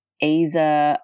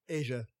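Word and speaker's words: Asa: There's no I. It's A-S-A. I'm not arguing with you. I'm just asking for an explanation of why that's incorrectly pronounced. – There are several different ways to spell the name that is pronounced Asia.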